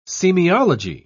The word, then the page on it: semiology: British English